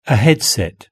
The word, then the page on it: a headset: British English